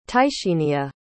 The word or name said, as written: tysheenia